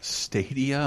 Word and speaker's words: Stadia: Like things that like Stadia, am I saying that right? – Yes.